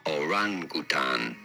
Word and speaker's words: orangutan: You do want to stress on the second syllable, the ran syllable. Orangutan.